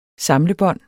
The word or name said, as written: FORSAMLET